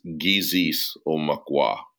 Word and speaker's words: Gizis Omukwa: And so when you pronounce it in Ojibwe, it's Gizis Omukwa. So Gizis means sun. And the O is kind of like what connects it. And Mukwa is bear, solar bear.